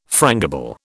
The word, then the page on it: frangible: British English